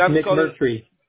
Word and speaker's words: McMurtry: No, it's McMurtry, like a tree at the end.